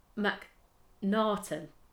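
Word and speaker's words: Macnaghten: Melville McNaughton, I think is how it's said. It's got like the G and the H, but it's like silent.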